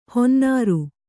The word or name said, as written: ಹೊನ್ನಾರು